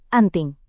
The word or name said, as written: anting